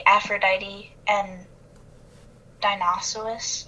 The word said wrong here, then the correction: Dionysus: How did you say that again? – Dionysus? – Dionysus. Oh my god. I'm so sorry.